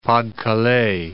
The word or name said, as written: Pas de Calais